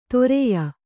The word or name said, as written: ತೊರೆಯ